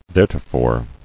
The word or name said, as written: Theretofore